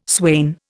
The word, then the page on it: swain: Indian